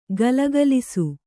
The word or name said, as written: ಘಲಘಲಿಸು